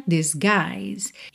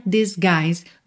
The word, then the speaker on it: disguise: Mas aí é porque nós temos L-E-Y no final, tá? Aqui é o sorriso, smile, smile. Can't disguise, você junta o T de can't com o D de disguise. Can't disguise. Disguise, nesse G, o I, olha, o U não é pronunciado, esse I é aberto, longo.